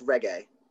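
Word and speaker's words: Regé-Jean: Reggae John Page, is that right? – I think it's a hard G. I think it's Reggae. – Reggae, okay.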